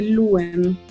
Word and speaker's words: Loen: Eh, hay un lugar que se llama, se escribe Loen, se dice Luen.